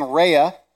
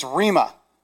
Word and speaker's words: rhema: I kept on saying rhea, and it's rhema. There's an M in there.